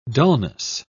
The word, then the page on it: dulness: British English